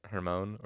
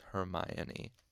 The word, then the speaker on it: Hermione: But yeah, trying to say Hermione uh, I didn't realize we didn't realize that it was uh, pronounced like Persephone um, for a long little while, so we were pronouncing it with like like Hermone or something like that. Hermione or Hermone or whatever. We were just like, how do we say this? And uh, it took us a little while to figure out it was Hermione.